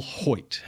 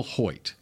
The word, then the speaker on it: Hoecht: Number 97, Michael Hoyt. That's how you pronounce his last name. – Good job, because I would not have known. – H-O-E-C-H-T, Michael Hoyt.